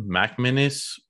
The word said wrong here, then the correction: McManus: McManus. McManus. McManus.